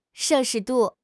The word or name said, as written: 摄氏度